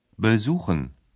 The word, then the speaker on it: besuchen: При някои глаголи представката е неделима. При изговарянето тя не е под ударение. Такива са, например, представките B и R. – Besuchen erzählen. – Глаголите безухен и ерцелен образуват миналото време, перфект, с помагателния глагол haben и своето минало причастие.